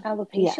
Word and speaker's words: alopecia: Alopecia? – Alopecia? – Yes.